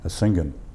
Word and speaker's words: Asingan: And we had to set up a headquarters at a town called Asingan. – Would you spell that? – A-S-I-N-G-A-N.